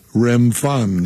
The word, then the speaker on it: Remphan: and I've never pronounced this word, Remphan, is that correct? – Remphan, yes.